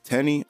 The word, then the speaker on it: Teni: Tenny, I believe is how you pronounce her name, T-E-N-I.